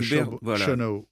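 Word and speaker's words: Schönau: Alors, le terme cathare est tiré des écrits de Saint-Augustin, hein, par le moine, alors je prononce mal, Egbert de Chenau. – Voilà, moine bénédictin du monastère Saint-Florin de l'abbaye de Chonner. Alors là aussi, Chenau.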